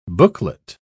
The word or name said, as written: booklet